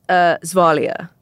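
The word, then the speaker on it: Gvasalia: And on a practical level, a lot of people get his name wrong, his last name wrong. – That's true. – It's Zvalia. – Yes, but spelled with a G. G-V.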